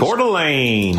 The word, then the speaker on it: Coeur d'Alene: Coeur d'Alene. – Coeur d'Alene? – Yeah, it's Idaho.